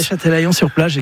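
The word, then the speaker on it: Châtelaillon-Plage: Alors, vous n'avez pas resté très longtemps non plus, vous revenez en France et vous allez, je voudrais pas l'écorcher, à Châtelaillon-sur-Plage, c'est comme ça qu'on prononce. – Oui, Châtelaillon-sur-Plage, exactement, à côté de La Rochelle,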